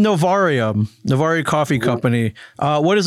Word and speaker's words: Novaria: And my brother recommended the name, and I was like, it might be hard for Americans to pronounce our R because it's Novaria.